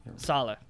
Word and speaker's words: Saleh: The New York Football Jets hired 49ers DC Robert Saleh. Is that how you pronounce it? – Saleh.